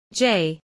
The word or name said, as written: j